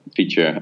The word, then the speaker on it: Phiture: So Feature being spelled deliberately confusingly to make sure that nobody can pronounce it properly. But we pronounce it P-H-I-T-U-R-E.